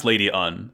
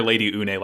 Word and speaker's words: Lady Une: she is pronounced Lady Un. So I will no longer be calling her Lady Une like I thought she was called last week.